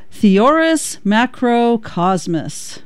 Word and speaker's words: Theoris Macrocosmos: The song is called Theonius. Let me pronounce this properly here. Theoris Macrocosmos.